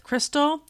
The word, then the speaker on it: Christal: Overcomer with Crystal.